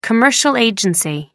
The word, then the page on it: commercial agency: American English